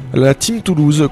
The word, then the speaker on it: La Team Toulouse: La Team Toulouse, comme ça se prononce. Team comme la team et puis Toulouse.